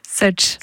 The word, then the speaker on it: such: On dit pas suck? On dit quoi? – Such. – Such, ok. – Ça veut pas dire la même chose.